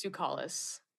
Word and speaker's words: Tsoukalas: My last name, it's very Greek and very hard to pronounce. I'm sorry, but it's Tsoukalas.